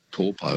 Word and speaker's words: Taupo: Now it's called Taupo. – Taupo, yeah, that's right. It's just the vowel pronunciations are a bit different. They don't really have that owl sound, like cow, meow.